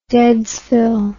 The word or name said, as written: deadsville